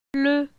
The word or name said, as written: Ple